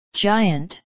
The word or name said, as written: giant